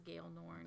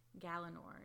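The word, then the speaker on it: Galenorn: I thought it was Gail Norn. – Definitely. – Until I started listening, and then I heard Gallinorn, and I was like, oh, I've been saying that wrong.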